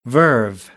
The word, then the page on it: verve: American English